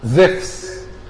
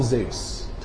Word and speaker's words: زيوس: وبولس كان معاه واحد تاني اسمه برنابا، قالوا على برنابا انت بحسب ترجمة الفاندايك زيفس. زيفس ده هو نفسه زيوس اله اليونان الاعظم، لكن نطق الاسم مختلف. لو هتنطقوا باليونان الحديث هتنطقوا زيفس، لو هتنطقوا باليونان القديم هتنطقوا زيوس. تمام؟